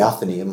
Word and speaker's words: Athenaeum: Is it the Athenaeum Theatre or is it the Athenaeum? – The Athenaeum.